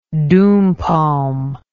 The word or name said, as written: doum palm